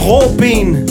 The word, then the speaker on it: Robin: Du er sådan et medicinsk vidunder Robin I virkeligheden, du danser, så dit navn udtales Råben Det vil mange sige Jeg er